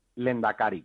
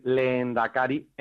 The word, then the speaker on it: lendakari: A partir de ahí, en realidad, si, cuando lo pronunciamos pronunciamos dos es, tampoco habría ningún problema en escribir leendakari en redonda con su H y sus dos es, eh, porque esa secuencia de e h -E, eh, también forma parte del español, aparece en rehén, por ejemplo, ¿no?